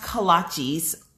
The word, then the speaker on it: kolaches: Pronounced kolaches.